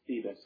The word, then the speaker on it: Thiebes: My last name is actually pronounced Thebes, but no big deal.